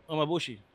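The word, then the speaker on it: umeboshi: Umebushu. – Umebushi. Oh my God, that shit. So they Umebushu. Did I say that right? – Umebushi.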